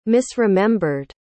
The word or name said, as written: misremembered